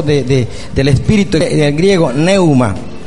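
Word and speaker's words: pneuma: P-N-E-U-M-A. Y se pronuncia neuma.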